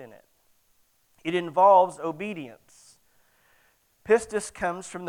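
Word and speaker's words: pistis (πίστις): It is pistis, okay? That is the appropriate term. My wife wanted me to make sure, because I'm not good at English, all right? But she said, you need to make sure that that's the way you pronounce that.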